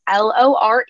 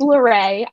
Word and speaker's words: Lore: So on YouTube, you can find me Isabella, and then my last name is Lore. It's pronounced Lorray.